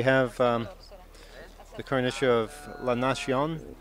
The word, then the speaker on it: La Nazione: And in English, that would spell Nazi 1. The way it's spelled, I never realized that before. It's La Nazi 1, but obviously in Italian it's pronounced differently. La Nazione.